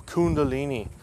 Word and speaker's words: Kundalini: And then you have Kundalini. I think I pronounced that right. It's cool. Kundalini.